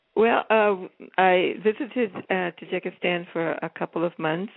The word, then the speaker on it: Tajikistan: So have you ever been to, am I saying it right, Tajikistan? – Tajikistan, yes. Yeah, I went to Tajikistan.